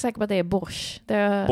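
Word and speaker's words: borsjtj: Men det stavas ju borsch. Det är något te där som inte får vara med i uttalet.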